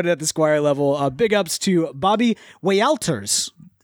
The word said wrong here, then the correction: Walters: That would be Walters. – It is Walters.